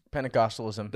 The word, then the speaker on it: Pentecostalism: Pentecostalism.